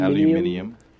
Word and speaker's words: aluminium: Aluminum or aluminium. – Aluminium. – Even though there's no additional I, they just pronounce it differently. – Isn't there? – No. – Oh, wow. – Yeah, no, they just say it weird.